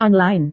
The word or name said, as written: online